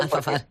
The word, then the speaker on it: Alfafar: Alfafar, sí. – Decime si lo digo bien, Alfafar, porque no sé decir bien las zonas.